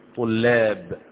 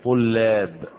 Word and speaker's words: طلاب: طلاب، اللام مرققة، طلاب مش طلاب، طلاب، تمام.